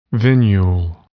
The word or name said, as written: venule